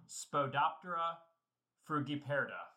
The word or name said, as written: Spodoptera